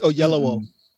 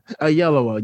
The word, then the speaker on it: Oyelowo: It's Oyelowo. – Oyelowo.